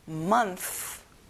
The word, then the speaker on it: month: 달, 월, month. 끝에 TH 발음 잊지 마세요. Month.